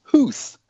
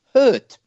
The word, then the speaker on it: Hoedt: Now, I was intrigued how on the commentary yesterday, Hoedt is pronounced Hooth, according to everybody. Yeah, and of course it's not. It's pronounced Hurt. – Hurt. – That's his name.